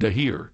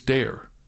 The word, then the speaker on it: Dahir: And if you're wondering why some people call him Dahir, in Lebanon, it's pronounced Dahir. Here in America, it's Dare. Christopher Dare, D-A-H-I-R.